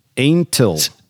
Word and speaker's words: Antal: So Nimrod, the director, Nimrod Anatol. – How do you spell it? – Anatol? A-N-T-A-L. – Anatol. – Antil. Antil. – Antil. – Antil. Antil. – Yeah.